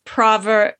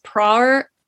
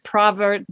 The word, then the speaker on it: Prawer: We've been thinking the whole time it was Ruth Prawer Jhabvala, but because she was from Germany and they pronounce the W as a V, I believe it's Ruth Praver Jhabvala.